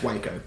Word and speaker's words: Waco: Kid, the Waco kid. It's pronounced Waco, but. – Waco kid.